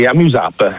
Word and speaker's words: Amuse App: Qual è il nome preciso? È Amuse App, in modo che si possa leggere sia all'italiana che all'inglese, cioè come funziona? – Sì, Amuse App, noi lo pronunciamo così.